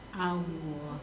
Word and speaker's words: Allah: Allah. – Yes. Ya, jadi A itu tipis, lohnya itu yang tebal. Karena huruf lamnya yang ditebalkan. Fakhimil lama min ismillah, ya. Jadi cara bacanya Allah.